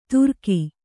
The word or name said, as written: ತುರಕಿ